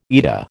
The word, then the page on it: eta: American English